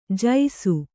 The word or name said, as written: ಜೈಸು